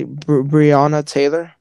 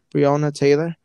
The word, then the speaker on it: Breonna Taylor: Breonna Taylor. Breonna Taylor.